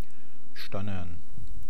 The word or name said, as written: stannern